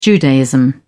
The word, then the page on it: judaism: British English